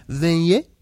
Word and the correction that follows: Jean-Claude Van Damme: Van Damme. – Yeah, Van Damme. – I'm sorry, Jean-Claude Van Damme.